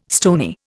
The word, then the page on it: stony: Indian